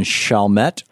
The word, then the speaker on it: Chalmette: And Shannon watching Free Speech TV in Chalmette, Louisiana.